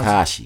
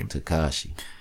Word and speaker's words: Tekashi: Like, how do you pronounce Tekashi. – It? Tekashi. Mm-hmm. See, I'm fucking it up already. – Tekashi.